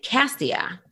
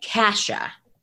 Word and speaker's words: Cassia: So in my head, when I was reading Matched, I was pronouncing it Cassia. Then I get the audiobook and they're pronouncing it Kasha. And I was like, oh, okay. So I was wrong.